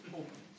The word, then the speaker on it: Hope: Hope.